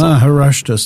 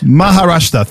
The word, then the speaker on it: Maharashtra: Maharashtra state. – Maharashtra.